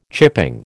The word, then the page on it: chipping: American English